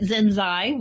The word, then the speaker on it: Zenzei: It's Zenzi or Zenzai, Z E N Z E I. – Right. Yeah, we've been pronouncing it Zenzai. We haven't been told that we're wrong yet.